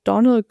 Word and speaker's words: Domhnall: Und der gute Mann heißt Donald Gleason. Ist ein Ire. – Donald Gleason. – Das schreibt man D-O-M-H-N-A-L-L. Ist ein irischer Name.